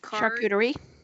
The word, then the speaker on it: Charcuterie: Charcuterie. – Yes, there you go. And cheese plate featuring Burton's Maplewood Farm Applejack Brandy Barrel Aged Maple Syrup. – Interesting. – So, on a charcuterie plate? – Yeah.